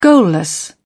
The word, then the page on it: goalless: British English